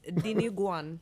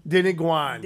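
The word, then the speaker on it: Dinuguan: What's den gin? – All right. – That's blood soup. – Oh, okay. – That's not blood. How do you pronounce it? – It's Diniguan. – Diniguan.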